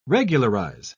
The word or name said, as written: regularize